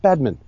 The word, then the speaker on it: Badman: It's pronounced Badman. Yes, the way that Benedict Cumberbatch would pronounce it. So yes, David Badman. Yes. Don't draw out the consonants or the vowels.